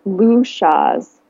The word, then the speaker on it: Luschas: Um, Luschas.